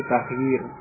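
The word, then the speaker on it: taghyir (تغيير): Kalau ianya dua itu taghyir. Berbeda ya.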